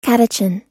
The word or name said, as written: catechin